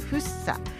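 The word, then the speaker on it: 福生: それでは今週はですね、東京都にありますフッサ、服に生きると書いてフッサ、これはですね、あの市長が言うには、幸せをゲットできる街フッサというところで、字のごとくということでね、ここのアメリカンストリート、ベースサイドストリートをご紹介したいと思います。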